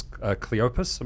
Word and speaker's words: Cleopas: One disciple was Cleopas.